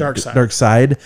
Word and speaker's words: Darkseid: But like with, is it Darkseid? Am I saying that right? – Darkseid.